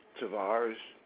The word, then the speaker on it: Tavarris: Tavarris, T-A-V-A-R-R-I-S.